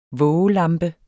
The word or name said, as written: vågelampe